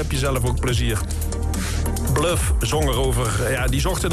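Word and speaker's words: Bløf: En dat wordt uitgesproken als Bluff.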